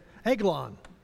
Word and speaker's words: Eglon: So in Judges chapter 3, verse 12, so the children of Israel served, and this is how you pronounce it, Egg, E-G-G, Lawn, L-A-W-N, Eglon.